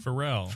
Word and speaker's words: Pharrell: How do you say his name? – Pharrell? – Pharrell.